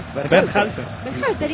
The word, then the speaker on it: Berhalter: Berhalter, ¿y qué dije yo? – No, impecable.